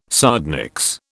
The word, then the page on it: sardonyx: British English